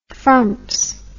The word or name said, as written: frumps